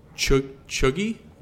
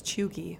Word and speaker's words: cheugy: Chuggy? Am I saying that right? – I think it's Chuggy. – Chuggy? C-H-E-U-G-Y. Chuggy.